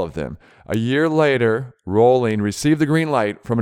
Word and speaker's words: Rowling: It's Rowling. Rhymes with bowling. It is not Rowling.